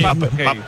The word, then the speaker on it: Pape Gueye: Pap Gay, el que llamamos Pape Gueye en todo el mundo, sí, es Pap Gay.